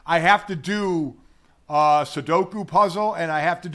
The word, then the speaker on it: Sudoku: And is it, I always say it wrong. Sudoku? Is that what? – Sudoku, yeah.